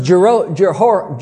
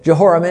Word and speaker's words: Jehoram: And his name was Jehoram. That's how you say it. Jehoram.